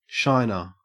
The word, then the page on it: shiner: Australian English